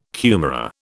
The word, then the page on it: kumara: British English